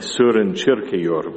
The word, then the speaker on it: Søren Kierkegaard: It's a theme that I take from the Danish philosopher Søren Kierkegaard, or I understand he should be pronounced something like Søren Kierkegaard, but I won't do that.